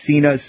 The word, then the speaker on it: Cina: The homeopathic remedy most indicated for parasites, although not the only one, is Sina, spelled C-I-N-A. – Sina. – Sina, oh, C-I-N-A, okay.